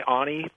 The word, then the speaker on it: Ani: in the A-N-I, Ani Papyrus. If you're familiar with the A-N-I, it's pronounced Ani Papyrus, or the Ani Tablets.